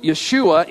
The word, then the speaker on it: Yeshua: In Greek, that name is pronounced Jesus. In Hebrew, Yeshua. In English, it comes out Jesus.